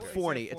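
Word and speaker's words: Fourni: He's from Forni. Look up Forni. It's spelled F-O-U-R-N-I. F-O-U-R-N-I.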